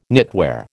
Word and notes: knitwear: American English

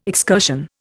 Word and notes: excursion: Indian

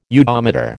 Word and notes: udometer: American English

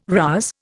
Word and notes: rouse: Indian

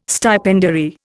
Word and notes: stipendiary: Indian

